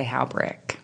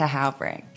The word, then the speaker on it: Halbrick: It's German, so I think they would say Horbisch or something like that, but I say Halbrick.